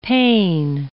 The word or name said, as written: pane